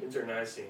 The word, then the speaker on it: internecine: I do not think I pronounced that correctly. The kind of warfare where you kill your friends too. Interceding, interceding, internecine, internecine. Damn, first time saying that and not reading.